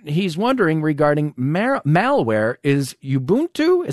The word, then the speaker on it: Ubuntu: Yeah, and I pronounce it Ubuntu, so okay. The people you talk to, you might hear something different.